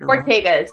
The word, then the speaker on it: Ortega: Ortega, correct, yeah.